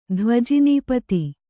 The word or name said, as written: ಧ್ವಜಿನೀಪತಿ